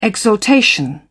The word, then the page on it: exaltation: British English